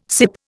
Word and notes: sip: Indian

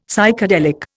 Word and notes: Psychedelic: Indian

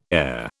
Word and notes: ere: British English

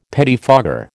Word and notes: pettifogger: American English

